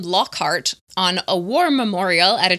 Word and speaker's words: Lockhart: And then some fans have also noted that Lockhart, which is spelled L-O-C-K-H-A-R-T, sounds like Lockhart, H-E-A-R-T, you know, which maybe, you know, he locks up the brave hearts of others with his memory charms.